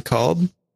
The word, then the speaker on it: Kolb: We got John Kolb. I think that's how you say it. K-O-L-B. Is that Kolb? Kolb. Yeah. I think.